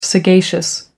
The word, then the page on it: sagacious: American English